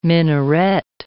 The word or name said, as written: minaret